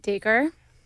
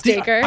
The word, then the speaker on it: Dacre: That totally grabs the attention of Decray Montgomery. Did I say his name right? – Dacre? – Decray? – Wow. No, it's Dacre.